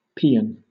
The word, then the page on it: paean: British English